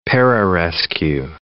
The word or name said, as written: pararescue